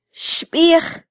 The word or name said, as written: xbij